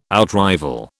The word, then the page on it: outrival: British English